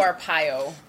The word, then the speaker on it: Arpaio: Arpaio.